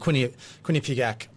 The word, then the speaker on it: Quinnipiac: I was looking at the Quinnipiac poll, if I said that right, that came out today.